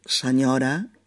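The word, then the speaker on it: senyora: Por ejemplo, señora en catalá se escribe S-E-N-Y, ¿no? Pero se pronuncia igual que señora en castellano, como una ñ.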